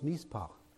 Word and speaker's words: mishpat: Now, the word for justice or justly is spelled M-I-S-H-P-A-T. It's pronounced mizpah.